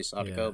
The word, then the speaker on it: Sadako: I think the pronunciation is usually, the emphasis is usually on the first syllable, so probably Sadako, but I could be wrong.